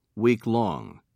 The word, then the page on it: weeklong: British English